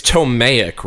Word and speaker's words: Ptolemaic: It's like a silent P. Like Wait, it's Ptolemaic, right? – P, yeah. P-T-O-L-E-M-A-I-C.